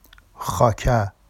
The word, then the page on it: xɑca: Vafsi